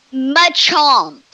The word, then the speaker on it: Machamp: And I love when the Ditto morphs into, I don't know, do you say, is it Machamp or Machomp? – Machomp.